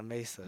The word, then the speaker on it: Mesa: It's Mesa, Mesa. – Mesa? – That's how you say it.